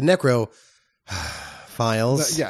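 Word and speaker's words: Necrofiles: But I was like, okay, well, now the Necrophiles. – Wait, hold, you're supposed to say it like this. The Necro files. – It's the not necrophile, like, you know, it's Necrophiles, like X-Files or something, right? – Which I do appreciate the title.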